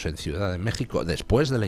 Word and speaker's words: México: La pronunciación es J. Y de hecho, pues en México tienen ustedes pueblos como Guanajuato, que se pronuncia como México con J, y que se escribe con J, cuando durante todo el siglo XIX permaneció la coexistencia de las dos grafías, Guanajuato con J y Guanajuato con X pronunciado J.